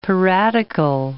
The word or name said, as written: piratical